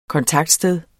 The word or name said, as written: kontaktsted